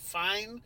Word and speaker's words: Fiennes: Fine. It's spelled like Fiennes, but it's fine.